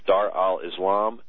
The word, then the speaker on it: Dar al-Islam: In Arabic, it's pronounced Dar al-Islam and Dar al-Harb, H-A-R-B.